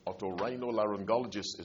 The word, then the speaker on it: Otorhinolaryngologist: Otorhinolaryngologist is the long, tongue-twisting name.